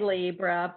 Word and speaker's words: Libra: Is it Libra or Libra? It's that L word with the balances. – Well, I say Libra, but you know what? The truth of the matter is it should be Libra, but because it's not a long I. And so I hear you can ask actually our guru on Thursday, our astrology guru, but he pronounces it Libra because I've heard him.